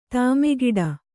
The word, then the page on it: ಧಿಮ್ಮಿಕಿಟ: kannada